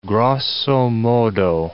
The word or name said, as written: grosso modo